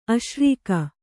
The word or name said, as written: ಆಸರಿಕೆ